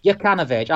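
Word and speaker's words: Jokanović: Jukanovic.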